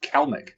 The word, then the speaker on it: Kelenic: What are your thoughts in Kelenic? – had a hat trick last night too. And then from watching the broadcast, his name's pronounced Kelnick.